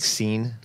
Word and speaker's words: Xeen: X-E-E-N. Anybody know how to do that? Is it Xeen? – Xeen. – That's Cine lens. – Like Xeen? – I just call it like a Xeen.